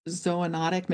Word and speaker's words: zoonotic: Well, your book points out that emerging infectious diseases since 1940, 60% of them are what is known as zoonotic. Maybe I'm saying that incorrectly. – No.